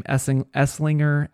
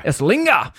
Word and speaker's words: Esslinger: Go to somewhere like Essinger. And I don't know if that's actually how you say pronounce their name. Esslinger. – That's how I would say it.